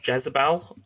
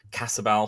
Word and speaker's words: Jetsabel: J-E-T-S-A-B-E-L, which may be pronounced Jezebel, or a version of, but in the song he says Cassabel, so I'm going to go for Cassabel throughout the description.